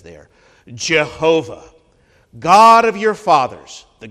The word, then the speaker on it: Jehovah: It's traditionally pronounced Jehovah, occasionally now Yahweh.